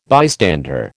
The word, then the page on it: bystander: American English